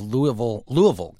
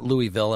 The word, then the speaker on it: Louisville: Well, Robin, yesterday on the show, we spoke with the mayor of Louisville, Kentucky. I'm saying that right, not Louisville.